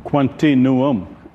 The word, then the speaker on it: Quantinuum: How do I pronounce the name of the company with that W at the end? – It is just as it is, Richard. Quantinuum.